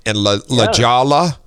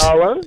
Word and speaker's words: La Jolla: You know, when you come from the Midwest, H's and J's are pronounced a little different than they are here. Versus La Jolla and El Cajon, anyway.